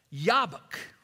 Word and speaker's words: Jabbok: Every J in Hebrew is pronounced like a Y, all right? And so in Hebrew, Jacob wrestled by the jabbok is Jacob, Yawebek, Yabak, right?